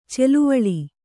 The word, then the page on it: ಛಲವೇಳು: chalavēḷu